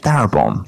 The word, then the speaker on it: Darabont: How do you pronounce it? Darabont.